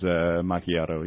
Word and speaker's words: macchiato: How do you pronounce this? Macchiato? Did I get it right? Macchiato? – I believe I heard it on NPR's Macchiato, yeah. – Macchiato.